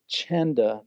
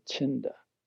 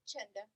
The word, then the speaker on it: Chenda: Someone asked me recently, is Chenda the proper pronunciation, or is it Chinda? – Chenda. – It is Chenda. Okay, I told him right then. I want to make sure we're pronouncing your name right. Chenda. Okay, the Cambodian way, right? Good. Peace and love. There we go. Very fitting. All right. Like Chenda. Almost like a J sound.